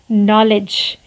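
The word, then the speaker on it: knowledge: नॉलेज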